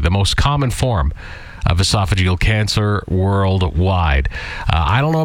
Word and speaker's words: esophageal: we're at a higher risk of getting esophageal cancer. Is that how you say it? – Yeah. – Unfortunately, the survival rate for like esophageal cancer is a horrible one to get.